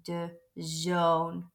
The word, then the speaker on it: zoon: And again, son starts with an S, so we say it with a Z, the zoon.